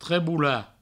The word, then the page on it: trebolar: treboula